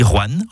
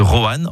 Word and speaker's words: Rouen: Vous avez remarqué comment je dis Rouen en une syllabe plutôt que Rohan en 2 syllabes ? C'est comme ça qu'on y dit dans la région.